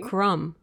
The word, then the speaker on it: crumb: Like cookie crumbs. The B is silent. – How about the word crumb? – Even like crumb. British people don't say the B.